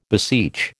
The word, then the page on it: beseech: American English